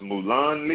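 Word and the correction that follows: mullein: I think they pronounce it like mullen.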